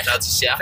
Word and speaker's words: Val d'Isère: Val d'Isère. Is that how you say it in French? – Yeah, Val d'Isère.